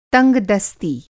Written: तंगदस्ती

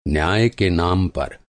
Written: न्याय

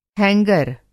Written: हैंगर

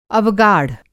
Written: अवगाढ़

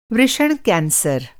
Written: वृषण

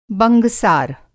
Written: बंगसार

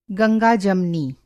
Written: गंगा-जमनी

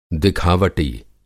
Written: दिखावटी

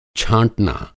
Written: छाँटना

